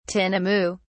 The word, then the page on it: tinamou: British English